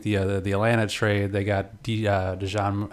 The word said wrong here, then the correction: Dejounte: Oh, DeJounte. – Like, I was like, I need a sandwich. Give me some mustard. You say his name. – DeJounte, man. DeJounte. – DeJounte Murray. – That's it.